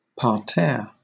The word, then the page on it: parterre: British English